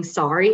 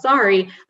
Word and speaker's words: Sari: What they didn't realize at the time was Sari is spelled S-A-R-I, the same way that the Indian clothing sari is spelled. And just coming from Boston, where people have an interesting way of pronouncing their R's, that combined with just like my name being in the dictionary pronounced sari, a lot of times people just say my name is Sari. So the conversation usually goes, Sari, and then I say, it's Sari.